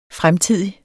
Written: fremtidig

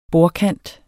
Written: bordkant

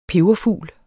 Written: peberfugl

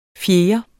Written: fjerde

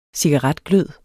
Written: cigaretglød

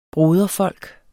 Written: broderfolk

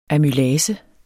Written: amylase

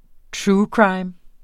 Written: true

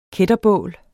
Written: kætterbål

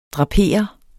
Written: drapere

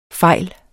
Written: fejle

